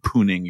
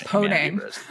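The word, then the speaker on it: pwning: I believe it's pronounced poning. – Oh, I've always pronounced it poon. – No, we have to get into that later. It's definitely poning, but pooning is incredible.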